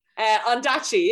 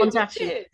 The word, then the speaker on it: Ondaatje: And not only that, but won the Royal Society of Literature Ondaatje Prize. I'm not even know if I'm saying that right. – Ondaatje, yeah, it did. – Ondaatje, yeah.